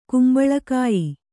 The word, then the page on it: ಕುಂಬಳಕಾಯಿ: kumbaḷa kāyi